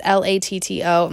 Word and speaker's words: Latto: But I'll just tell you the song Muop by Lotto. So Lotto is Latto and the song is Muwop, which is spelled Muwop.